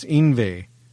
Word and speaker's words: Yngwie: No, just Yngwie. I-N-V-E-I. That's how you pronounce it. Yngwie. – Yngwie. Okay. – No, you're saying the G. There's no G. You don't Yngwie. Pronounce the G apparently.